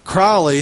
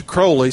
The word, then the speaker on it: Crowley: It used to be Crowley, then he changed it to Crowley so that it would rhyme with holy, which he was the opposite.